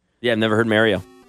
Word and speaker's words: Mario: Tell me I'm pronouncing it wrong, for God's sakes. Jesus. – Mario? – Yeah, what's Mario? The difference? Mario? I mean long A versus short A? – I don't think anybody calls it Mario Kart.